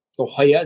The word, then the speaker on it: La Jolla: Out on the West Coast in Torrey Pines, in La Jolla, California. La Jolla, as they pronounce it.